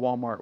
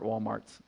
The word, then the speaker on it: Walmart: I mean, where they like call Walmart Walmarts. They just don't know the S is silent.